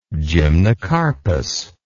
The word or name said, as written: gymnocarpous